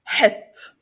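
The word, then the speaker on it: Het (חית): Výslovnost. Úplně ideální, byla taková ta guturální. Het. Jo? Het. Nevím, třeba, to by bylo třeba het. No ano, třeba to slovo hřích. Het.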